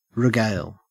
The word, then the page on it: regale: Australian English